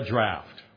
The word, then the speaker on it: draught: draught." That's how you pronounce that. Draught.